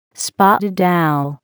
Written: spotted owl

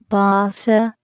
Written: basa

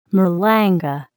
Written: malanga

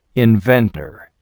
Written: inventor

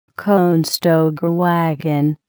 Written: Conestoga wagon

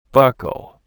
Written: buckle

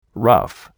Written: rough

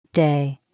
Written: Arbor Day

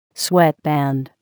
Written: sweatband

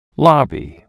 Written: lobby

